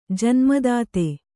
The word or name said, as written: ಜನ್ಮದಾತೆ